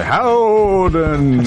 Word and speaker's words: Howden: Howden!